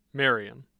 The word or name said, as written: Marion